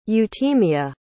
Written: euthymia

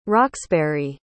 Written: roxbury

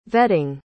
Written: vetting

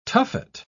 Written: tuffet

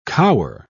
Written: cower

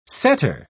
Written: setter

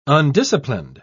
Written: undisciplined

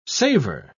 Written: savor

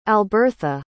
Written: albertha

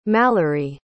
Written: mallory

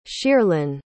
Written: Shierlyn

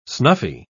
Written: snuffy

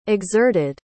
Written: exerted

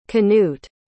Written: knut

